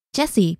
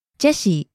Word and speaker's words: Jesse: And my name, for example, also changes. Jesse becomes Jesse in Japanese.